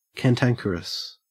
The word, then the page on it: cantankerous: Australian English